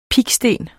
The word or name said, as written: piksten